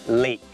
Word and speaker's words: lake: ับอ๋อคำว่าบึงน้ำคือ lake ไม่ได้ออกเสียงว่า leg แปลว่าขาแต่คำนี้มันออกเสียงว่า lake